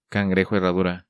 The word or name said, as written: Cangrejo Herradura